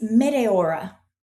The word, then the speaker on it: Meteora: I think it's Meteora.